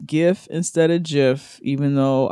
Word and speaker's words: GIF: I appreciate you both, but please, it's pronounced GIF, not JIF, like the peanut butter. Just drop the tea off gift and you're good.